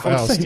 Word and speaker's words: Faust: No, it's pronounced Faust.